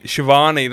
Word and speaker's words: Schiavone: Tony Schiavone's, I can't pronounce his last name. – Shivani, that's it.